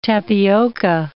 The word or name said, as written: tapioca